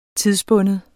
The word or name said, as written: tidsbunden